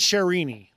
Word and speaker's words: Sherini: Now, is it Sherini, Robert? – Sherini. – Okay, so like Sonny and Sherini, that kind of thing.